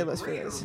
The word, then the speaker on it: Los Feliz: ladies and gentlemen, there's been a power outage at Gabe Liedman's estate, as well as all of the Los Feliz. How do you say it? Los Feliz, right? You say Los Feliz.